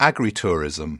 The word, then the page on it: agritourism: British English